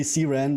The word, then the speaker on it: CRAN: Yeah, I learned it's officially CRAN, but... – Oh, okay. I've always called it CRAN.